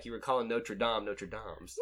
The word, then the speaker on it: Notre Dame: Okay, so they went to Notre Dame's. Meet. – Wait, hold on. You've already messed up. Notre Dame's? – How do you say it? – Notre Dame. Not Dame's.